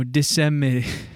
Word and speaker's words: disseminated: Her tweets were considered to have, quote, decimated. No, disseminated. – Spell it. – D-I-S-S-E-M-I-N-A-T-E-D. Disseminated. Yeah, disseminated information to disturbing. – Okay. – Yeah, not decimated. Disseminated.